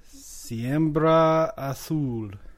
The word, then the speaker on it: Siembra Azul: Siembra Azul.